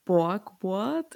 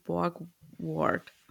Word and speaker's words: Borgward: Er meint dann, dass er beim Streifendienst einen blauen Borgward, ich hoffe, ich spreche das richtig aus, seiner Automarke. Ich glaube, es wird ausgesprochen Borgward.